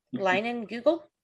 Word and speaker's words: Leinenkugel: I didn't know how to say that. I see that beer all the time. I had no idea how to say it. I still can't say it. Leinenkugel? – Leinenkugel. – Leinenkugel? – Leinenkugel. – Oh. Okay. Whatever you say.